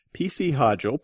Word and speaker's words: P.C. Hodgell: P.C. Hodgell, Pat, is the author of the Kencyrath series.